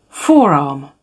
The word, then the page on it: forearm: British English